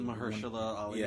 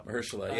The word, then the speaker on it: Mahershala: Mahershala Ali. – Yeah, the one who won for Mahershala, that's how you say it? – I believe so. – Mahershala, yeah.